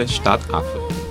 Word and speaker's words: Stadtaffe: Stadaffe. Macaco da cidade. – Macaco da cidade. – Se minha separação de sílabas está certa, é Stadaffe. – Está correto.